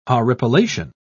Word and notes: horripilation: British English

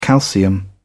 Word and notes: calcium: British English

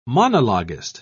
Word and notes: monologuist: British English

